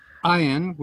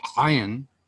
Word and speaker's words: Ayin: And then we have Ayin, which I don't know how to characterize it phonetically. It's been a long time for me. – It's the sound that you make when an olive is stuck in your throat.